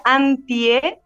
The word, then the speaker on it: Antje: Eh, sí, mi nombre se pronuncia Antie, bien chileno, bien chileno. En realidad es alemán.